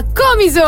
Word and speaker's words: Comiso: Comiso con l'accento sulla prima O. Non è Comiso.